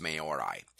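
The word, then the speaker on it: Māori: which is the home of the Moray culture. And or Maori, I think it's pronounced Maori.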